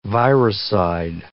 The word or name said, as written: virucide